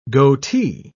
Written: goatee

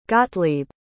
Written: gottlieb

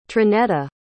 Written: trinetta

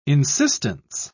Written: insistence

